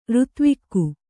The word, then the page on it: ಋತುವಾಗು: řtuvāgu